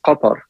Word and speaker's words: Qatar: Well, in Arabic, it's Qatar. Qatar. So, I mean, in English, you obviously you have some letters in there, the kaf and the ta, that are very difficult, I think, to pronounce for anyone who doesn't speak Arabic. So, in English, they typically say Qatar or Qatar. Those are the two. – Qatar. – Those are the two pronunciations that I've heard in the media here.